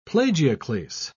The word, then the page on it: plagioclase: British English